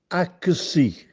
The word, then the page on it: Acchessì: Castellanetano